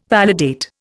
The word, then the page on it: validate: Indian